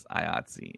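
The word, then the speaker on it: IATSE: and that's how people I've come to find out pronounce it. It's IOTSI.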